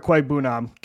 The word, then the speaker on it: Okwuegbunam: With I love the trade for Albert Okwuegbunam. Got the name right first time. – Bang on. That was Alberto. Proud of myself. Gino had to tell me how to say it before the show, so I'm not going to take full credit.